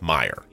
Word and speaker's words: Meijer: CVS Pharmacy, Walgreens, Amazon, Walmart, Rite Aid, Target, Kroger, and this one that I'm being told is pronounced Meijer, even though it is spelled Meijer.